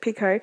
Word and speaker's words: Picoult: That's how you pronounce it, Picoult.